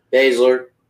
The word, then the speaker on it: Baszler: We got another match, and I guess, let's see, Shayna Baszler. Did I pronounce her name right? – Baszler.